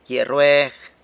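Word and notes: gyia rrueg: San Lucas Quiaviní Zapotec

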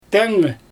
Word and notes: teŋgɛ: Xyzyl

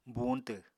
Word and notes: buuñtw: apatani

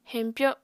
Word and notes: heñpyo: Apatani

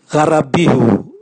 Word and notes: garabihu: Raga

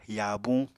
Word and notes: yaabuñ: Apatani

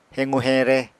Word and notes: hequ-heñre: apatani

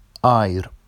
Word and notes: ɑjr: Vafsi

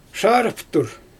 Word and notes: ʃaarɯftur: Xyzyl

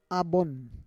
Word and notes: abon: Roon

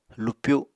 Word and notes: lupyu: Apatani

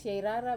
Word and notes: sair rara: wamesa

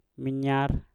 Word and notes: minyar: Mpur

